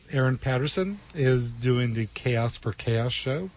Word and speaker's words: KAOS: A lot of people get confused about that when they see the poster, but it's chaos as in, you know, insanity. – What this show is crazy. But it's Chaos for Chaos, and it's spelled KAOS, which stands for the Kimberly Ann O'Neill Scholarship.